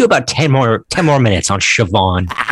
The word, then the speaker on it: Siobhan: Siobhan is how that name's pronounced. It's Siobhan. It's an Irish name. – Really? – Oh. – Yep. – A silent B? – Siobhan. – That's Gaelic, baby.